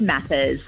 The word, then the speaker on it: Mathers: It's Mathers. – Mathers. Like math class.